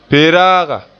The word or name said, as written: pe-raaga